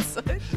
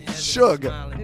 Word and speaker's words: Suge: Isn't it Sudge? – She said Serge Knight. – I said Sudge. – Suge. – It is? – Yeah, like sugar. Suge.